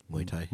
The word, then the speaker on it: Muay Thai: Muay Thai.